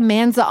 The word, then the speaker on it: Amanza: I was like, her name is Amanza.